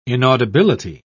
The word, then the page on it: inaudibility: British English